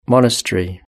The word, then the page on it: monastery: British English